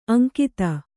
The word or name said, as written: ಅಂಗತ